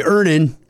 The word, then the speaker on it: Earnin: Earnin. – Earnin. – E-A-R-N-I-N is pronounced Earnin. I don't know how else you would pronounce it, but they really want it clear that that's how to pronounce it.